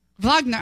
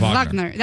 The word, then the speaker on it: Wagner: When you read his name in the chat room, is his name's actually pronounced Wagner. – Wagner, I'm sorry. It's Wagner.